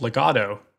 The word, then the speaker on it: legato: This technique is called legato. In Italian, it means smooth or bound. The way I like to remember it is it rhymes with gelato, the Italian word for ice cream, which is also pretty smooth.